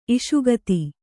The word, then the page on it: ಇಷುಗತಿ: iṣugati